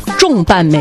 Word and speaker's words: 重瓣: 到公司门口放声大笑，那不应该重瓣吗？多重瓣吗？ – 但不是，就是它的标准读音读重，重瓣玫瑰。